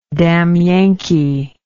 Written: damn yankee

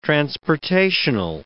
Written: transportational

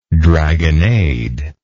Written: dragonnade

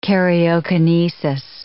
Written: karyokinesis

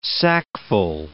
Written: sackful

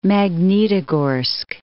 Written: Magnitogorsk

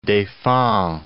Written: Deffand, du

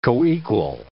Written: coequal

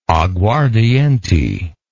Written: aguardiente

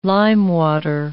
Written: limewater

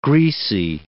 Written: greasy

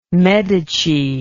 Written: medici

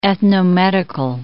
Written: ethnomedical

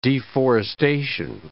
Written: deforestation

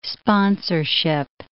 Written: sponsorship

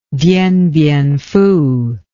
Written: Dien Bien Phu

